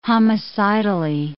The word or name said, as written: homicidally